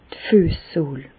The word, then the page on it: d' Füessohl: Bas-Rhin; Reichshoffen